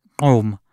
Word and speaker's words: Gqom: Si scrive GQOM, però si pronuncia OM, e diciamo si pronuncia così perché è una parola in lingua Zulu,